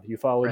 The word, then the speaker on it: ufology: There's a great scene near the end where the UFO, how do you say it? Ufology group, right. Kind of, I never have actually said that word aloud. Ufology, right, yeah.